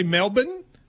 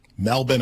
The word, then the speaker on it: Melbourne: So you're saying Americans say Melbourne. – Yes. – But apparently, and I didn't know this, Australians say Melbourne. – Melbourne apparently is what they...